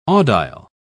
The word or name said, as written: audile